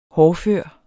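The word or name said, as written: HÅRDFØRE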